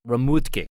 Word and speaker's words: Remhoogte: The winery is spelled R-E-M-H-O-O-G-T-E, but it's pronounced Remoetke.